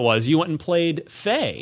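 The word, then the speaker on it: Fe: It's pronounced Faye, but I just keep calling it Faye because it's spelled F-E.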